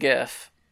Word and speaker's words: GIF: It's GIF and GIF. – Well, no. GIF is wrong.